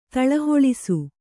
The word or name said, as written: ತಳಹೊಳಿಸು